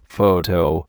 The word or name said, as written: photo